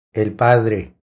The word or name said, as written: El padre